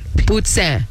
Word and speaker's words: poutine: P-U-T-S-I-N. Poutine.